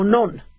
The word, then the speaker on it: non: How about oh, no. Oh, non. Okay, so I've been saying it right.